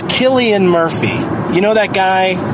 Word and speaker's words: Cillian: It's pronounced Cillian. – Yeah. And some people got so mad at me on some message board, just screaming about how, get it straight, it's Cillian.